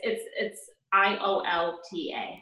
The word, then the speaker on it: IOLTA: Could you, what'd you say? IOLTA? Am I saying that right? – IOLTA. Yeah. It's IOLTA.